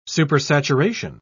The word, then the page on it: supersaturation: British English